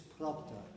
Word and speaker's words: propter: It's propter. Propter, a very dull second syllable, propter. Propter always explains, I don't know anything about Latin, so I go propter. No, propter. It's a short, dull sound.